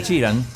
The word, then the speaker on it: Sheeran: tengo a Ed Sheeran.